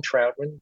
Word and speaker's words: Troutman: It's all about just my name, Alan Troutman, but you got to spell it right. Alan Troutman. – Yeah. Not trout like a fish.